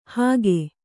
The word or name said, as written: ಹಕ್ಕೆ